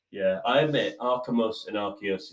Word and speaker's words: Arceus: Archimus. – Oh, that is so, so close. It's Arceus. – Arceus, not Archimus.